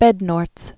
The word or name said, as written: J. Georg Bednorz